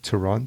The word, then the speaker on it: Tirana: Tur- yeah, Tirana. – Tirana. T, T, I'm saying it wrong. It should be a soft R in the back of the mouth, but okay.